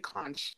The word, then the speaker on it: conch: The conch, well, a lot of Americans say conch. So I guess how to avoid it sounding like cock.